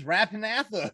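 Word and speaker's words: Rappan Athuk: I learned that I've been pronouncing Rapanathuk wrong this whole time. It's Rapanathuk. I'm like, oh, well.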